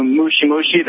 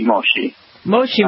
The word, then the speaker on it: moshi moshi: Like when you said you knew mushi mushi, that's actually incorrect pronunciation for hello over the phone. It's mochi moshi. – Moshi moshi. – Yes. Mushi means bug. You essentially said bug.